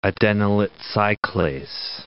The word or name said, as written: adenylate cyclase